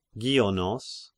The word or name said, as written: Gionos